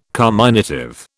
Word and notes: carminative: British English

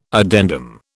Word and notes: addendum: British English

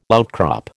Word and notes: outcrop: American English

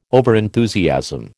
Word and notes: overenthusiasm: American English

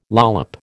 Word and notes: lollop: American English

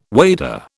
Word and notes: wader: British English

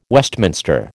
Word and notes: Westminster: American English

